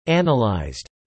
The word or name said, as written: analysed